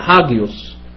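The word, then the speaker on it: هاجيوس (ἅγιος): مش ده اللي احنا ايه مش ده اللي احنا ايه النطق القديم اللي انا اعرفه بيتنطق هاجيوس، الفا جاما يوتا اوميكرون سيجما.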